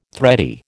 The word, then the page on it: thready: American English